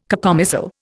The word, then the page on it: cacomistle: Indian